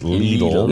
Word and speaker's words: Lidl: Beach police just stopped by to tell me that the grocery store Lidl is pronounced Lidl.